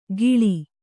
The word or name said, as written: ಖಿಳಿ